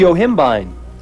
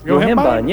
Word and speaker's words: Yohimbine: It's called Yohimpb. – Caller, pronounce that for us. – It's an alpha 2 antagonist, which has been suggested beneficial for men. – That's Yohimbine. – Yohimbine, yeah.